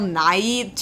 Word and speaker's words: Nighy: When we saw it the first time, every time Bill Nighy, just to be clear, that's Bill Nighy, two syllables.